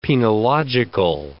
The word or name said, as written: penological